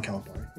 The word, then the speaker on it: Calipari: No John Calipari's, or however the heck you say his name. – Yeah, John Calipari.